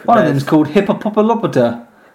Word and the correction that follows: Hippolyta: Hippolyta, I think it is. – We'll go with that.